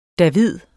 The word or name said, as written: david